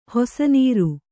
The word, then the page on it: ಹೊಸನೀರು: hosa nīru